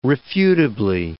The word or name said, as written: refutably